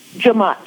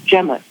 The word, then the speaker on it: Jemmott: J-E-M-M-O-T-T. – J-E-M-M-O-T-T. – That's my maiden name. – Okay. – And if you were from Barbados, you would probably pronounce it Jemott. – Okay. – But my mother liked Jamott better, so...